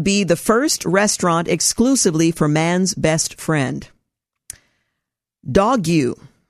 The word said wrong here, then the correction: Dogue: which rhymes with, well, is it Dogue? Okay, it rhymes with Vogue, so it's Dogue, opened last month in the city's trendy Mission District.